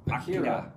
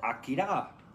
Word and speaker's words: Akira: You gotta pronounce it correctly. – Akira. – I don't even know what you're saying. – Akira.